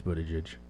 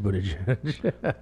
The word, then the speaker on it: Buttigieg: Buttigieg. Is that how to pronounce his name? – Yeah. – Yeah, I think it's Buttigieg. I'm pretty sure it's Buttigieg.